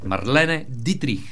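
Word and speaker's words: Marlene Dietrich: Но през 1947 го открива Марлен Дитрих. – Има защо с това произношение, бе? Тя е германка, бе. Марлене Дитрих.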